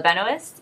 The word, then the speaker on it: Benoist: in class Melissa Benoist, yeah? – Yeah.